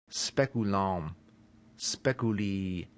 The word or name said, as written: speculum